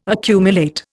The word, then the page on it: accumulate: Indian